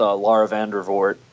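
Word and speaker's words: Laura Vandervoort: So primarily was on Smallville with Lara Vandervoort. I hope I'm pronouncing her name right. I butchered names, so I'm going to let that. – That's how I pronounce it. So I think that's right.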